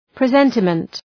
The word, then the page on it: presentiment: prı’zentəmənt